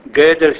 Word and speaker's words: Gödel: Allora, questa illusione dura poco più di 30 anni, perché questo signore, Gödel, si scrive G, O con la umlaut, D, E, L.